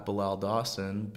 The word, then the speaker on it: Bilal Dawson: So you can follow me on Instagram at Bilal Dawson, BILALDAWSON, because Bilal, I feel like some people don't know how to spell it, so there you go.